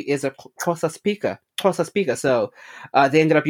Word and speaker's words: Xhosa: Xhosa. Mm-hmm. Well, it's pronounced different. Like, some people might say Xhosa or Xhosa. I like doing the click because it's a vocal flex, so why not?